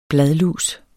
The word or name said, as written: bladlus